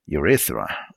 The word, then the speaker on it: urethra: Urethra.